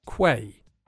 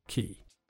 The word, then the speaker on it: quay: Loads of English people don't know how to pronounce the word quay, because it looks like it should be pronounced quay, but it's just quay.